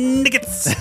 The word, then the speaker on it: knights: Just like that weird, dumb pronunciation of knights. He's just pronouncing the Middle English of it. He's just pronouncing it the way that he's supposed to do it. And he's supposed to sound stupid, but he's actually saying it correctly.